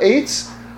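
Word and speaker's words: Eitz (עץ): We have to spell Eitz, get to the place where we spell Eitz, Aleph Tzadi. It would still be pronounced Eitz, but we're turning the Ayin into an Aleph.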